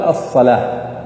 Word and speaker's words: الصلاة: الصلوة، ويوضع بعد اللام ألف صغيرة، فالواو ما تنطق، صح؟ يقال الصلاة. هل أحد يقرأ الصلوة ولا الزكوة؟ ما في، صح؟ وإنما كلهم ينطقون الصلاة والزكاة.